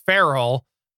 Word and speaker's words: Ferrell: And you can find Chris at the Chris Farrell. That is spelled F-E-R-R-E-L-L. Did I get that right? – Yes.